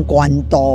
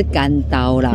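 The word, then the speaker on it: 關渡: 可是现在大家很多人都照着关渡这两个字的华语来念，都念成关刀哦。真的耶，这个我蛮常听到所以赶快的不敢塞乎，真的要念对是干刀啦那么我听说呢，关渡这边好像有这个动物园，是不是跟风水有关？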